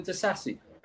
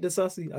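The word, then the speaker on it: Disasi: I actually rate De Sassi. I think the criticism. – That's what I mean. – I would say the reason why, one of the reasons is because his name rhymes with disaster, so it's easy to.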